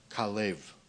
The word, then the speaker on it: Caleb: If you get into the Hebrew and you listen to what the name is, his name is actually pronounced Kalev. And we just mess that all up, call him Caleb.